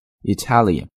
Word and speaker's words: Italian: 在这里呢我们也稍微提一下意大利的或者意大利人这个词的发音， Italian，重音在 ta 上面， Italian。